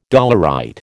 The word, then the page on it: dolerite: American English